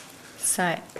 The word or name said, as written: sae